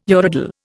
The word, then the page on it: urodele: Indian